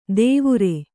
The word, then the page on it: ದೇವುರೆ: kannada